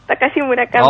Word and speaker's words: Takashi Murakami: Takashi Murakami.